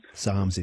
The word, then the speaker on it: Psalms: It is a silent P, and it is the longest book in the Bible. Starts with P, though. What is that book? – Psalms. – Psalms is exactly right.